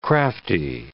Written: crafty

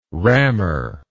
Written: rammer

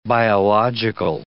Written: biological

